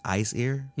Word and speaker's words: Aesir: Um, and if you look at the Aesir, or I guess it's pronounced Ice Ear, mm-hmm.